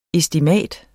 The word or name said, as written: ESTIMATETS